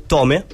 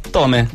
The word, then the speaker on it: Tomae: Lui si chiama Tome, voglio chiamare alla latino con Littongo. – Ok, perché si scrive Tomae. Sì, però effettivamente Tome.